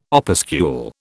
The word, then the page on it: opuscule: British English